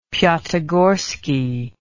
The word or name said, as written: piatigorsky